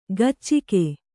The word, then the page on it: ಗಚ್ಚಿಕೆ: gaccike